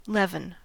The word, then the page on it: leaven: American English